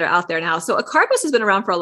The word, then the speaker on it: acarbose: What is acarbose? Acarbose, am I saying that right? – Oh yeah.